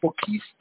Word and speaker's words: Boquist: That was Representative Boquiste, is that, is that how it's pronounced? Boquist? Uh, B-O-Q-U-I-S-T? – Boquist. Mm-hmm.